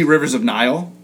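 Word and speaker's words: Rivers of Nihil: We're going to see Rivers of Nile. Which, did I say it right? I said it right? – Okay. Yes, finally. Right. You got it.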